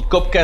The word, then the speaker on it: Copka: Mi nombre es Rob Kopka, me pueden seguir en TikTok, Instagram. Rob es mi nombre, de Roberto, y Kopka es mi apellido, se escribe con C-O-P-K-A.